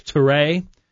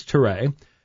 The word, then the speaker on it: Touré: It looks like Touré is the name. I'm sorry? T-O-U-R-E with an accent. So we're assuming it's Touré.